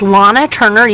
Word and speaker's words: Lahna Turner: My name is Lana Turner. You spell that L-A-H-N-A, Turner, T-U-R-N-E-R.